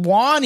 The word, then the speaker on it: Juan: I can fucking Juan even, man. I know that J is not a fucking actual J, or it'd be John.